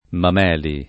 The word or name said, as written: Mameli